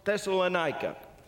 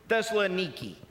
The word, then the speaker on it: Thessaloniki: And then they moved over to a place called Thessalonica. Actually, the way they pronounce it today, for those of you who are there in Greece, Thessaloniki.